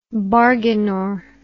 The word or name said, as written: bargainor